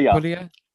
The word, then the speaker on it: Puglia: Am I saying it right now? – Puglia. – Good.